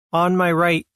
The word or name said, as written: On my right